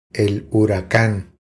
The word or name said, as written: El huracan